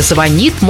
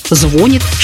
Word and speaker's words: звонит: В ближайшем будущем ударение в слове «звонит» может измениться и переместиться на первый слог.